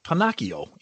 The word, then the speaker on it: Pinocchio: Pinocchio, right? – Pinocchio. Yeah, hard C. It's not a sh, it's a k.